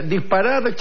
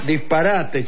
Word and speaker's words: disparate: Por ejemplo, uno de los versos decía disparad chabacano. Yo dije, Lope mide bien los versos. – Sí. – Si fuera disparad chabacano, tendría siete sílabas. Se necesitan ocho. Disparate chabacano.